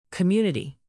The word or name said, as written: community